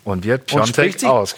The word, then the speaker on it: Piontek: Aber er spricht sich Piontek.